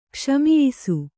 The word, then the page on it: ಕ್ಷಮಿಯಿಸು: kannada